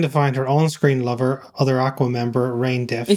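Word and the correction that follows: René: It's Renee, actually it's Renee. It's Renee, the accent on the second E.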